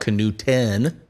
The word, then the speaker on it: Knudten: I've got an old Wisconsin license plate up there with my last name, kind of phonetically spelled out, Canuten, you know, so that's helpful.